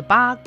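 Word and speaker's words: Ba (八): Ba, which means 8, sounds like fa, the word for prosperity, wealth, or fortune.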